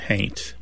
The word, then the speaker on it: haint: Somebody might spell it haunt, but pronounce it haint.